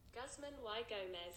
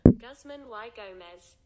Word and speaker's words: Guzman y Gomez: Guzman Y Gomez. – Yes! It says a Y. W-A-I. – Guzman Y Gomez.